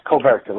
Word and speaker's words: Coverta: Is it Coverta? Is that how you pronounce it? Yes, Coverta, like Omerta Coverta.